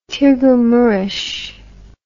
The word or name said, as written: Tirgu Mures